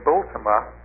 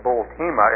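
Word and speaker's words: Bultema: At the beginning of the century, there is Harry Bultima, or Bultima, as some pronounce his name.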